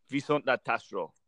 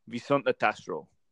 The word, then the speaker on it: Viszontlátásra: So my goodbye is obviously the Hungarian for goodbye tonight, and it's a really hard word to say, and I'm going to spell it for you first. It's spelled VISZONTLATASRA. – Good. – And you pronounce it, I think, Viszontlátásra. – Say it again. – And that is goodbye in Hungarian.